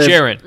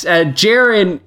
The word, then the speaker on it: Jaren: Jaren. – Yes, Jaren can be,